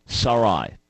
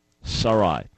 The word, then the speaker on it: Sarai: All right, so the correct pronunciation is Sarai. Sarai.